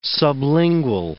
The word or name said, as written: sublingual